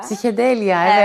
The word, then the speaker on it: Psikedelya: Yanlış telaffuz edebilirim ama Psiketelya. – Psikedelya, evet, Evet.